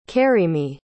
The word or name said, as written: karyme